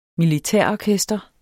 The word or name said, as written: militærorkester